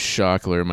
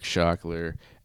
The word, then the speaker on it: Shokler: My artist name is Shockler. My name is Eric Shockler, S H O K L E R.